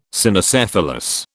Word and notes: Cynocephalus: British English

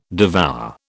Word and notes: devour: British English

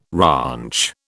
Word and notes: ranch: British English

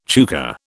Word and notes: chukar: British English

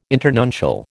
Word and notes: internuncial: American English

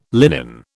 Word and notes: linen: British English

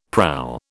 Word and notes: prow: British English

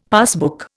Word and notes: passbook: Indian

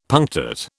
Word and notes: punctate: British English

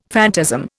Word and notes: phantasm: Indian

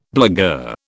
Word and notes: blagueur: British English

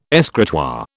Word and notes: escritoire: British English

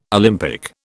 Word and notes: Olympic: British English

